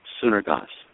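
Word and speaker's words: synergos (συνεργός): That's where we get our word that I first started off with tonight, synergos. And I believe that's a good pronunciation of it. That's the Greek word itself, synergos.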